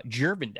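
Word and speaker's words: Gervon: Jervin Dexter, soft G, could end up being a first-round defensive lineman.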